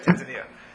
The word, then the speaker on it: Tanzania: Tanzania.